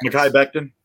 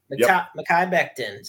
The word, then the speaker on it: Mekhi Becton: Mekhi Becton of the New York Jets. – Mekhi Becton. – Mekhi Becton. See, I knew I'd get it some way. But Mekhi Becton, who all the talent in the world, but he already suffered a season-ending injury in week one last year.